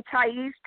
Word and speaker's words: Thais: You can also add my name, Thais, T-H-A-I-S. It's just like when you go to a Thai restaurant, it's spelled the same way with the S at the end.